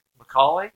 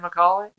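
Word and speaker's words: Macauley: Ed McKill. – Is that McCauley? – Yeah, my bad. Easy Ed McCauley? – Yep.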